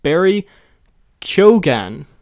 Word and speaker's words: Barry Keoghan: First of all, Kian after helping me out figuring or letting me know how to use Letterboxd, he also sent how to pronounce the new Joker's name, the guy, the actor, who we keep calling Barry Keoghan. He says, I'm almost certain the new Joker's name is pronounced Barry Keoghan.